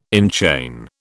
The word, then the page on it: enchain: British English